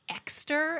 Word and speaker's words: Exter: And it's spelt like Exeter, but it's pronounced like Exter.